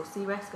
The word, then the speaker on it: CRISC: Um, your CISM or your uh, CRISC or CRISC as it's pronounced is good if you want to go into risk and strategy.